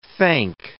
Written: thank

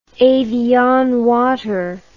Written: evian water

